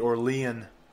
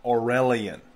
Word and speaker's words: Aurelian: Yale University has banned the Aurelian Honor Society. Excuse me, Aurelian. I pronounced it wrong.